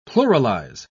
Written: pluralize

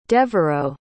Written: devereau

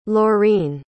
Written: lorine

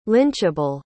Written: lynchable

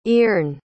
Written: ierne

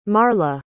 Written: marla

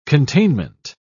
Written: containment

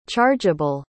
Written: chargable

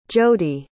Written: jody

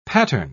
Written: pattern

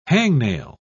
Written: hangnail

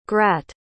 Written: grat

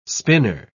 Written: spinner

